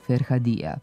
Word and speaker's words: Ferhadija: Na kraju moram da primetim da su reporteri Ferhat Pašinu džamiju u Banja Luci nazivali čas Ferhadija, čas Ferhadija. – Meštani tu džamiju oduvek zovu Ferhadija. To su nam potvrdili i slušaoci, time se rukovode i akcentolozi, te smo je tako i mi u emisiji akcentovali.